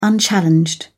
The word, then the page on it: unchallenged: British English